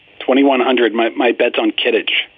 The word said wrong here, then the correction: Quidditch: Is that how you pronounce it? Quidditch? – Quidditch. Come on.